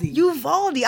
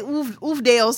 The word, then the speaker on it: Uvalde: Uvalde. I want to say Oofdale so bad. – I get it because of the way it's spelled.